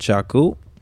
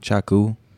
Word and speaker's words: Chaku: You know, every time I say my name, I'm like Chaku, and I'm like out like doing a gig. People, for some reason, think I'm saying Taco.